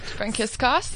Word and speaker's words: Frankiskos: Daniela, I'm going to say it wrong again, Frankiskos. Is that how we say your surname? – That's correct. Frankiskos.